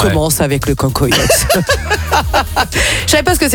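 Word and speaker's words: cancoillotte: Le cancoyote. – Oui. – Ça se prononce comme ça? – Oui, ça se prononce comme ça. – C'est-à-dire comme ça? – Oui, oui. – Cancoyote.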